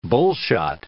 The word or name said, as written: bullshot